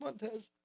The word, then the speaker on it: Montez: I think it's Montez.